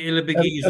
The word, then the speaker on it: Ilibagiza: Immaculee Ilibagiza. – Ilibagiza.